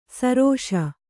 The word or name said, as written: ಸರೋಷ